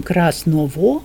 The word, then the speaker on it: красного: Значит, Ломоносов в грамматике пишет, где произносим «г», и он обращает особое внимание, что мы вообще не произносим «г» в прилагательных, а говорим «красного», в тот звук, а пишется «г», да, это вот.